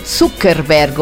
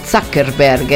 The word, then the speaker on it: Zuckerberg: L'attrice Scarlett Johansson, Mark Zuckerberg o Zuckerberg, a seconda di come si voglia pronunciare, Katy Perry, Emma, Giorgio Chiellini, il calciatore.